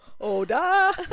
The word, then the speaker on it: Ouida: It's, it's written, that's even more O U I D A. Would never have thought that that was how you pronounced that.